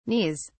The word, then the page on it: knees: British English